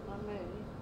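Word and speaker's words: Lemay: Anne Marie Lenay.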